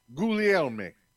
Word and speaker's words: Guglielmi: Can you pronounce that one, man? – Guglielmi. – Guglielmi. Okay, there you go. – Guglielmi. Guglielmi. – You'd think someone could pronounce other people's weird names. – I do. That's right. It's Guglielmi. It's an Italian Guglielmi.